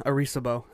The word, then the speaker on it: Arecibo: The Beacon in the Galaxy Project, BITG, reveals information about our solar system, human DNA, and the Arecibo. Can you pronounce that? – You're asking the wrong motherfucker. – Arecibo? – Yeah, Arecibo. That's how I would say it, yep.